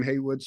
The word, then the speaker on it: Haywood: Find me on LinkedIn under my name, Brian Haywood, spelled with a Y.